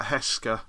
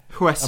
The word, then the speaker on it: Huesca: It's spelled B-L-U-F-F. – It's spelled with an H, but I couldn't tell it. It's something like Hesker. Hosker. – Correct, yeah. – Is that correct? – Yeah. – Mm. How do you actually pronounce it? – Huesca. – I'm assuming Huesca.